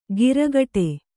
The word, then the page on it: ಗಿರಗಟೆ: giragaṭe